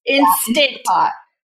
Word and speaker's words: Instant Pot: Your Instapot. – Instant Pot? I always say it wrong. I want to say it like Instagram, but it's Instant Pot.